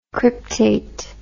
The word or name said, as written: cryptate